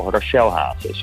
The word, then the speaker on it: Rachel Hazes: Hé, en dan Rachel Hazes, of zeg je nou Rachel? Ik weet het steeds niet. – Nee, je zegt Rachel Hazes, zo spreek je het uit.